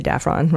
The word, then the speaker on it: Daffron: Her husband is a guy named Thomas Daffron. Or Daffron, it's probably Daffron, rhymes with saffron.